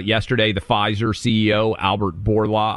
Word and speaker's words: Bourla: He prefers pronunciation Bourla. You have to roll the R.